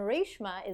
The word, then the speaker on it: Reshma: And Reshma is actually the way that it's technically supposed to be said,